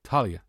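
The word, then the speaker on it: Taulia: How do you pronounce his name? Talia. Yeah, it's Talia Tungavaloa.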